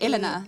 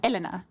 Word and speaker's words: Elena: A lot of people say Elena, Elena, Ilana. It's actually Elena. – Elena. It's not fun. – So Elena, which I think I get the most, is emphasis on the second E, but it's meant to be emphasis on the first E. So Elena.